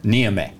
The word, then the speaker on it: Niamey: Niamey.